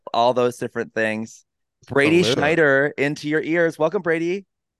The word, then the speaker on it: Schnieder: Quick German lesson. You always pronounce the second vowel. So mine is IE, which is why it's Schnieder. Kind of like how Diesel is IE, but you don't say Diesel.